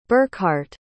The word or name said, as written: burkhart